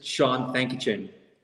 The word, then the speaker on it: Shaun Thankachen: Okay, it's actually pretty easy. It's pronounced exactly like how you look at it. It's just Shaun Thankachen.